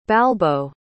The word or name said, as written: balbo